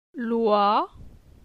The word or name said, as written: Loi